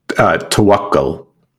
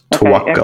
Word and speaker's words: Tawakkul: Wait, I want to say it right. Tawakkul? – Tawakkul. Yes. You said that. You were pretty close. Tawakkul.